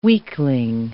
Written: weakling

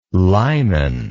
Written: lyman

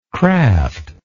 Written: craftless

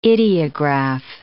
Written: ideograph

